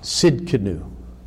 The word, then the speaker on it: Tsidkenu: The T is silent. Tsidkenu.